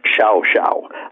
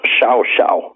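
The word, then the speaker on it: Llao Llao: It's spelled LLAO-LLAO, and it's pronounced Xiao Xiao. I have no idea how you get to that, but that's what it is, Xiao Xiao.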